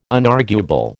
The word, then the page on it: unarguable: American English